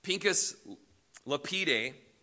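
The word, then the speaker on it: Pinchas Lapide: Pincus Lapide, is actually how you say it, was one of the leading Jewish historians of his day.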